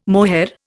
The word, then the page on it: mohair: Indian